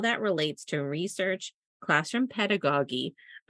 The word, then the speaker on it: pedagogy: Just little known fact, Casey just and I just looked up the pronunciation of that word because we've said the word like three different ways, and Google told us that it's pedagogy.